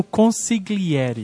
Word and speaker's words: consigliere: Assim, no mesmo nível do sotocapo, tem o consigliere. Que é uma palavra tão simples, mas que ninguém consegue falar. – Consigliere. – Mas, na verdade, eu acho que nem tem esse G, né? Na pronúncia.